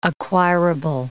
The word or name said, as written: acquirable